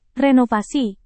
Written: renovasi

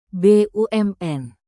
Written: bumn

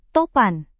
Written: topan

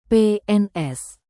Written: PNS

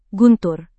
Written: guntur

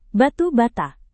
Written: batu bata

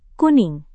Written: kuning

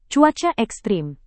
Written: cuaca ekstrem

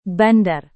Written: bandar

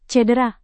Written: cedera

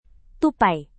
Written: tupai

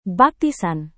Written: baptisan

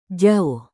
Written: jauh

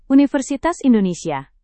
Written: universitas indonesia

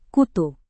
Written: kutu

